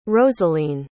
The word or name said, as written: rosaline